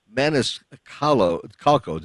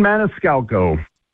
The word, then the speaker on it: Maniscalco: Let's talk about Sebastian Maniscalco. Did I mispronounce his name? – Sebastian Maniscalco. – Oh, I did.